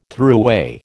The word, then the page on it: throughway: American English